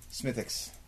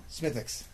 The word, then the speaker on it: Smithwick's: And the guy's like, he pours it, and he's like, Smithix. I'm like, what? Smithix. W is silent.